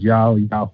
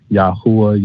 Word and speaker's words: Yahweh: And so for myself, I choose to pronounce it in that format as Yahweh. But most people, they pronounce it as Yahuwah, Yahweh, so, but for myself, that's my pronunciation of it.